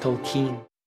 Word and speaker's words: Tolkien: It's pronounced Tolkien.